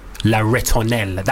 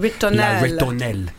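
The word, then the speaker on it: La Ritournelle: and I chose Sébastien Tellier's La Ritonelle. That's how you pronounce it. – La Ritonelle.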